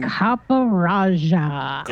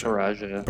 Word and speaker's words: Copperajah: Okay, guys, I'm going to need help with the pronunciation of that uh, big elephant Pokemon thing. – Copperajah. Yep, Copperajah. – Copperajah. Perfect. Thank you, guys.